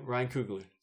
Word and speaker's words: Coogler: Ryan Coogler.